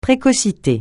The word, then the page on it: précocité: francais